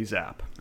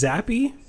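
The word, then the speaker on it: Zappe: They needed OT to beat Bailey Zapp. Is that how you say his name? – I think it's Zappy, but... – Oh, is it Zappy? – Oh, that's so much more fun.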